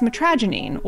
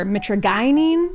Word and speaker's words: mitragynine: The active compound in kratom is known as mitragynine, or mitragynine, depending on who you're talking to. Either pronunciation is fine.